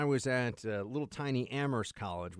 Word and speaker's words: Amherst: And you say, well, if you went to Amherst College, you don't pronounce the H.